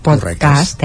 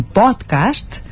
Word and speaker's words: podcast: aquests podcasts, podcast, eh, vigilem amb la pronúncia, eh, perquè en castellà és un podcast, en sí. canvi, en català l'hem de fer aguda, un podcast.